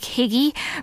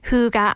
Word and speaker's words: hygge: Now, if you look at that word, it looks like higgy, but it's actually pronounced hygge, and Diane will tell us all about that.